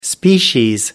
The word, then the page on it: species: American English